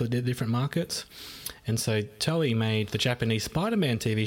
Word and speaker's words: Toei: So, in the late 70s, Wikipedia tells us that the Toei Company, I'm not sure how to pronounce that. – Toei, is it? – Yeah, they make Dragon Ball.